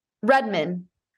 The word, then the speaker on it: Redmond: Redmond.